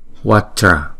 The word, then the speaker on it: Vajra: Chữ kế tiếp là Vajra, Vajra, chữ đó là hai âm, không? Chữ Wa và chữ Dra, chữ J này chúng ta đọc là J, Vajra.